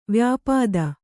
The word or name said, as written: ವ್ಯಾಪ್ತ